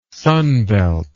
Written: sunbelt

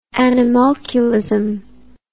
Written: animalculism

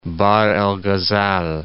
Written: Bahr al-Ghazal

